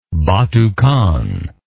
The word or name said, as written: batu khan